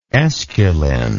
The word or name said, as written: esculin